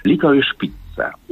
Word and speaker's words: Licorice Pizza: Licorice Pizza. Ja specjalnie wymawiam tak, jak to robi Alana Haim, która gra jedną z dwóch głównych ról.